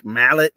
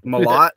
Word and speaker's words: Malott: I kept mispronouncing this man's name last week. I fucking called him Mike Mallet. It's Malott.